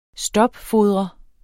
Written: STOPFODRES

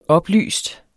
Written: oplyst

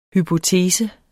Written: HYPOTESES

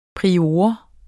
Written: prior

